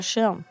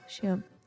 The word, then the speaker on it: Schirm: Schirm. Yeah. S-C-H makes that noise. But it's mad like how that makes that, but then English you'd say school and it's like that's not how you say it in English.